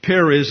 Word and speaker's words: Perez: Perez, this is also called Phares in other passages of Scripture, but because of Tony Perez, we'll pronounce it that way.